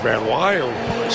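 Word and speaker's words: VanWey: He spells his name V-A-N-W, or capital W-E-Y. But it's pronounced Van Wy.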